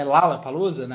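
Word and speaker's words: Lollapalooza: Só que o que, que a produção né, tá tentando fazer esse ano é aproximar a marca Lola Palusa, né, Lala Palusa, na né, pronúncia correta, é, do que é o Rock in Rio.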